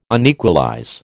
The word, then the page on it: unequalize: American English